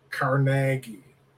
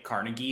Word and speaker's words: Carnegie: We had a history teacher in high school that pronounced it as Andrew Carnegie, not Andrew Carnegie.